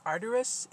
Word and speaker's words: arduous: He had reached an age where judging all of Israel in his circuitous route had become very arduous. Okay, the word I pronounced it arduous is actually arduous, spelled A-R-D-U-O-U-S.